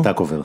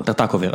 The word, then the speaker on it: טרטקובר: טרטקובר, איך מבטאים את שמו? טרטקובר. – טרטקובר.